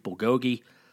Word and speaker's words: bulgogi: Even had some Korean beef bulgogi.